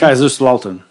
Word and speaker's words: Kaiserslautern: Kaiserslautern.